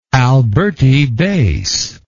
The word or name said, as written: alberti bass